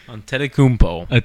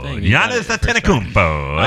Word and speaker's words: Antetokounmpo: Antetokounmpo. – Giannis Antetokounmpo.